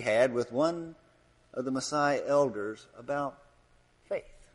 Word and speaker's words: Maasai: Well, so off he went to the Maasai tribe. I think I'm saying it right. Maasai, is that how you say it? Good. Thank you, Andy. It's good to have an African here who can tell us how to do this.